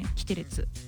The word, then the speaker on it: キテレツ: キテレツご紹介していきましょう。岩崎大将くん、井上瑞希くん、中村玲也くん、猪狩蒼弥くん、佐々木大光くんからなる 5 人組のアイドルグループ。グループ名なんですけど、あの発音としてはねキテレツで、